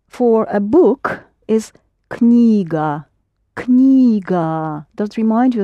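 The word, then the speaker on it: Knyga (книга): But for some reason you don't pronounce the K, and we do. Knyga is something that you put on your knee and read.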